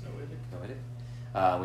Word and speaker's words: Noahic: So we have the Noahic? How do you say it? The Noah covenant? – I think it's Noahic.